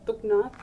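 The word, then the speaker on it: Thok Naath: Thoknoth. – Mm-hmm. – Did I say that correctly? – Yeah, Thoknoth.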